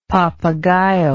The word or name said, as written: papagayo